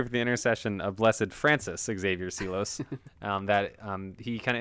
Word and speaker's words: Francis: Francis. – What did I say? – Francer.